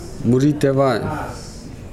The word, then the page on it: lalo de vae: Nukuoro